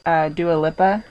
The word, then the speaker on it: Dua Lipa: And there's also fucking Don't Start Now by Dua Lipa. I think that's how you pronounce her name. – Yeah, it is.